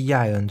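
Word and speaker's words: Seyn: 把这个 sein 这个词变了一点点，因为德语的 sein 是 sein 对吧，他把那个 i 换成了 y，其实发音还是一样的，变成 sein 了对吧，念法差不多，只不过含义区别很大了，这个词就不是德语普通意义上的那个 sein 了对吧，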